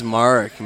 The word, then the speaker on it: Marek: Type my name, Mason Marek. – Spell it for us. – MasonMarek. – It's Marek, man. Marek? I've been saying the wrong Marek. Way. My apologies. – When I played baseball, it was Merrick.